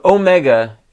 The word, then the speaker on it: Omega: So Omega has the long O sound. Omicron has the short O sound.